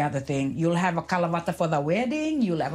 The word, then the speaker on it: Kalavata: So Kalavata, well, Kala, literally, well, is spelled K-A-L-A, but in the Fijian way.